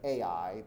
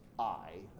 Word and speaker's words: Ai: It's often said Ai, but it's Ai. That's how you say it.